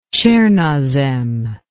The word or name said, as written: tchernosem